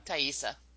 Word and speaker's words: Taissa: After Norman murders Emma, he dates a new character played by Taissa Farmiga. Is that how you say her name? – Thaisa. – Thaisa.